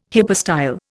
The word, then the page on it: hypostyle: Indian